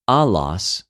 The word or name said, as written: ἀλλος